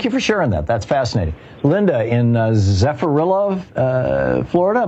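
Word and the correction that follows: Zephyrhills: Am I saying that right? – No, Zephyr Hills, Florida. – Oh, Zephyr Hills, two words.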